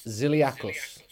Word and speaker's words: Zilliacus: Thomas Zilliacus. Is that correct, Thomas? – You know what? That was pretty good. Zilliacus is the way I would say it, but close enough.